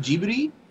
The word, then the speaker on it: Ghibli: Is it pronounced Ghibli? – It's actually pronounced Ghibli.